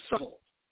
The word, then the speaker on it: subtle: This is a very slick individual, very subtle. Subtle. You don't hear the B, do you? The word subtle is not spelled S-U-T-T-L-E or S-U-T-E-L, subtle. No, S-U-B-T-L-E. The B is silent.